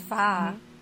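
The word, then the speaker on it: pho: Do you pronounce it fo or fo? – Oh my gosh, Damo's gonna kill us if we get this wrong. – It's fa.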